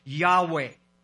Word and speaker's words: Yahweh: In the first instance, in the first verse, the first O Lord is the Hebrew word we pronounce Yahweh.